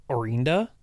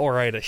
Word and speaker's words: Orinda: No, it's Orinda. No, not Orida.